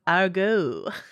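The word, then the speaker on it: Aargau: By actual sheer coincidence, the name of the plane was Argo. Did you hear my like French Swiss pronunciation of that, named after the region of Switzerland.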